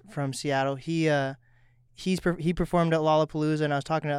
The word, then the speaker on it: Lollapalooza: Is it Lollapalooza or Lollapalooza? – You got it. First time, yeah. – Okay. – Lollapalooza.